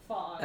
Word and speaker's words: Phog: It's spelled P-H-O-G. Fog.